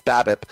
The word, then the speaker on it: BABIP: I'm not sure why I went with the way I pronounced it, you know, like 4 years ago. It's BABIP.